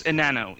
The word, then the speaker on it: Enano: So I gave sort of an intro as best I could to, and I don't even think I pronounced it right, Enano CMS right at the beginning, but why don't we start with Excuse me? – Go ahead. – It's Enano. It's Spanish.